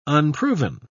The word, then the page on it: unproven: British English